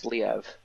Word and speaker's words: Liev: Liev? It's two syllables? – Yeah. Yeah. It says it's pronounced Liev.